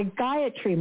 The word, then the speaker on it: Gayatri: The Gayatri Mantra.